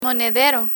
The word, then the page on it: monedero: Spanish